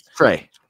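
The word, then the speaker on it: Trea: Is it Trey or it is Trey, right? – Trey. – T-R-A-I. I didn't know if I was saying it right.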